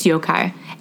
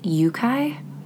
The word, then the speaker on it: yokai: It's yokai. And you know what sucks is that I thought it was yokai, but then in the phonetic spelling, it's spelled YOU/KI. So wouldn't you think it meant to pronounce it yukai? – I can see it.